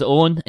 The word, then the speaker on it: Eoghan: Hi, my name is Owen, and you can find me on Twitter at howwerollowen. Owen is spelled E-O-G-H-A-N, because Irish spellings.